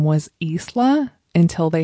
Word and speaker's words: Isla: And my daughter's name is Isla. But we don't spell it I-L-A, we spell it I-S-L-A, so the S is silent.